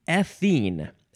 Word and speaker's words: ethene: Next is ethene. Ethene. Emphasize that first syllable.